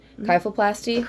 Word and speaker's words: kyphoplasty: Kyphoplasty? – Correct.